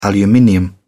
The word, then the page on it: aluminium: British English